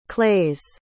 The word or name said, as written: clays